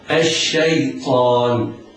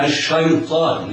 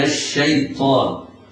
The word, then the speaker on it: الشيطان: الياء يتحرز القارئ من تفخيمها فلا تجذبها الطاء إليها، يعني لا يخطئ القارئ ويفخم الياء فلا يقول الشيطان، لا، وإنما تنطق مرققة هكذا: أعوذ بالله من الشيطان.